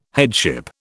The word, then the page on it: headship: British English